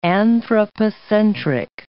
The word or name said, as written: anthropocentric